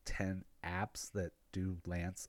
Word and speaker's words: LAANC: So they actually created a stupid, super easy way to gain access through the lance. The L-A-A-N-C, but everybody pronounces it as lance.